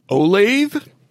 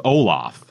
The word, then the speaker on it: Olathe: O-L-A-T-H-E. How would you pronounce that? – Olathe? – I thought it was Olaf. – Olaf? – But it was Olathe. – Olathe. That was my eighth guess.